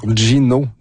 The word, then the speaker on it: Ginaud: Cet invité-là, c'est l'auteur et chroniqueur Fabrice Ville, mais tout juste avant, voici une archive de l'homme fort, Gino Dupuis. Gino avec, en fait, qui s'écrit G-I-N-A-U-D.